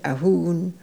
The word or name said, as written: Ahoon